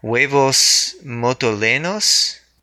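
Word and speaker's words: huevos motuleños: And we both like the huevos motolenos. Is that how you pronounce that? – Oh, yeah. Huevos motolenos.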